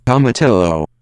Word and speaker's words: tomatillo: Tomatillo.